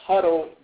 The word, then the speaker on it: Hudl: And that's Huddle, just spelled like H-U-D-D-E-L-E? – It's H-U-D-L. – Oh, H-U-D-L. Okay. – Yeah, H-U-D-L.